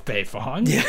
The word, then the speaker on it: Beifong: So, emphasis on the Bay whenever talking about Toph Beifong. She is Bay.